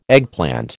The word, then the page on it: eggplant: American English